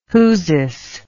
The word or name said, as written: whosis